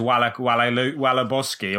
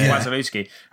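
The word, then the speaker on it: Wasilewski: I think it came from your lack of pronunciation knowledge of him that you thought it was Wallaboski or whatever. And we were like, no, it's Wazalewski. And then it was Wallace. That's who Wallace is. – That's Wallace, yeah. Marcin Wazalewski of Leicester.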